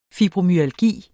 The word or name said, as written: fibromyalgi